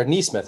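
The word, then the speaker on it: Nesmith: Yeah, I mean, look, like Naismith's a good player. Like, or Neesmith, I guess, is how he's pronounced. I've literally always said Naismith,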